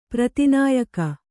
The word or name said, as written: ಪ್ರತಿನಾಯಿಕೆ